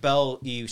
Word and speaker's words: Belle: Anyway, we saw Belle. – I'm not going to talk about trailers. – We'll talk about it later. We saw Belle. – Yes. – Do you pronounce the E in that? – No.